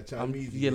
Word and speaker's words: Chymeezy: Am I pronouncing that right? – Yeah, Chimeezy.